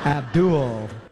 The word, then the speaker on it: Abdul: We gotta say it like that, man. It's creepy. Abdul. – That's how it's pronounced.